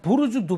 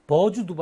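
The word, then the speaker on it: 부르주 (برج): 또 그다음 부르주 두바이인데 처음에 우리나라에서 소개할 때는 버주 두바이라고 했어요. 버주 두바이. 왜? BURJ, J로 그 J로 읽으니까 그걸 부르주도 아니고 버주로 읽었어요. 영어식으로 버주 두바이. 근데 나중에 부르주 칼리파가 나왔는데 그건 또 부르주 칼리파라고 읽어. 똑같은 말을 버즈 두바이 부르주 칼리파 읽으니까 그때서 깨달은 거예요. 아, 이게 아랍어로는 버즈가 아니고 부르주구나. 그래서 은근슬쩍 그냥 부르주 두바이로 바뀌었습니다.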